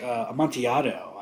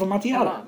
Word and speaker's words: Amontillado: It's like a great vintage Amontillado. I found it bricked up. I found a cask of it bricked up in the tunnels of Venice years ago. – Cask of Amontillado. – Amontillado, yes.